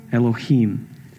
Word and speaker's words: Elohim: So that's our first interaction with God, Elohim. Just a little thing there. You might see it has an I there. Well, they pronounce those as long E's in Hebrew. So that's why I'm saying it the way that I'm saying it.